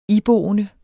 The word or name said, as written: iboende